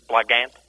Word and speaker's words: Blogant: Like, is that the Canadian pronunciation, Blogant? – Yeah, I guess. – Okay. – Blogant. How should I say it? – I think the accent's on the blog, not the Gant.